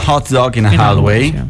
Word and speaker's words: Hot Dog in a Hallway: Il brano che abbiamo ascoltato si intitola Hot Dog in a Hallway, giusto la pronuncia? – Molto corretta, molto bravo, stai migliorando.